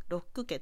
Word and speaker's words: Locke: タジー、なんですけど、まあ、リアルな設定のファンタジーで、えっ、ー、と、ロック家という、まあ、ロックって、あの、 LOCKE ですね。まあ、ちょっとスペルが違いますけど、鍵鍵は LOCK ですけど、鍵と同じ、こう、発音のおお家の名字の方、ロック